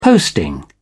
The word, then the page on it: posting: British English